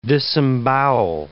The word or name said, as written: disembowel